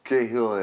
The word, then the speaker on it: Cahill: de sommige gasten die hoe, hoe spreek je dat op zijn Iers, die Dolores Cahill uit? – ja, Dolores sprak... Cahill, ja. ja. ja Cahill zei je toch?